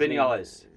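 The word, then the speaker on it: Viñales: Is Vinales, how do you say his Vinales. – Name? – Vinales.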